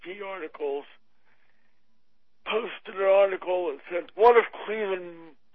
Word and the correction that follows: Cliven: How do you say his name? Cleveland Bundy? – Yeah. Well, Cliven.